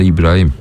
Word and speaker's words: Brahim: Niye Brahim diyoruz? Çocuğun adı aslında İbrahim. Öyle de telaffuz edebiliriz ama alışmışız Brahim Diaz demeye.